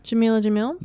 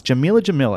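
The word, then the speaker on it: Jameela Jamil: Jamila Jamil. – Nice. Jamila Jamil.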